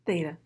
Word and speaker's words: Theta: As a member of Delta Sigma Theta Sorority, I probably said that wrong. – Theta, but it's okay.